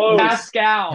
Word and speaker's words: Pascal: Pasquale? Pascal. – Pascal. Okay, I was one syllable off. – Mispronunciation. – Pasquale? – Just a mispronunciation. – Yeah. – Pasquale. – One syllable? You have an extra syllable in there.